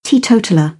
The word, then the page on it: teetotaller: British English